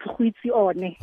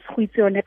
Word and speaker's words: Goitseone: Yes, it's Goitseone. – Goitseone. – Yes. – And your surname is Kanaka. – Yes, Goitseone Kanaka.